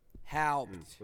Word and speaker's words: Haupt: So the best way I can describe saying my name is saying how with a PT at the end. It's Haupt. – Haupt.